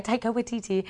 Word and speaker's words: Taika Waititi: Yeah, Taika Waititi, which you pronounced so well.